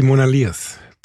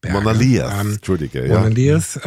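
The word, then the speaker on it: Monadhliath: Monadilet, oder wie spricht man die aus? Oi, sicher falsch. – Nein, das sind die Monaliers. – Monaliers kommt auch wieder aus dem Gälischen, wie sehr viel hier in der Kultur und in der Bezeichnung auch von Orten.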